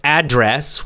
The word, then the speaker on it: address: The first one, address, strong on the first, the beginning.